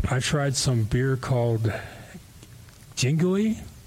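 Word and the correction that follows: Yuengling: I think that was Yingling.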